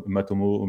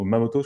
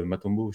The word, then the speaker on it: Matomo: Donc, euh, Matomo, Mamoto, je crois, c'est Matomo, je ne sais plus comment on le prononce.